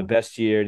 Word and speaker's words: bestiary: Your epigraph, you cite Fanon on the bestiary, the bestiary. Sorry, is it pronounced bestiary or bestiary? – Bestiary.